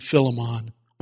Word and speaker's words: Philemon: I pronounce his name Philemon. Okay, some of you say Philemon, I say Philemon.